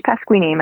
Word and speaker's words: Pasquini: It's Paige Pasquini, my first and last name.